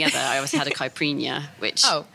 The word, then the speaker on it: caipirinha: So it's not pronounced Capriana, just so anyone knows.